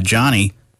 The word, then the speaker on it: Jonny: Uh, you can get a discount uh, if you use my name, uh, Jonny.